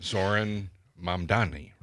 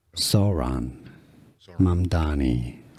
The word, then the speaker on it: Zohran Mamdani: Zohran or Zohran Mamdani. In English, pronunciation... Zoran Mamdani.